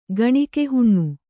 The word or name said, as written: ಗಣಿಕೆಹುಣ್ಣು